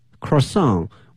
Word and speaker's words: croissant: It's a chocolate croissant with rich chocolate inside and outside。